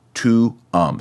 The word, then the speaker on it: tuum: Notice the vowels don't get blended like you might expect in English. You say both vowels. And since the accent goes on the second to last syllable, almost always in Latin, it's tuum. So the first U is a long one, and the second U is a short one, tuum.